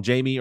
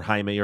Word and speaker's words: Jaime: So it's kind of nice for me, him having like three different pronunciations of his first name, because it allows me to mix things up a little bit. But yeah, if you want to call him Jamie or Jaime or Jim, all those are fine.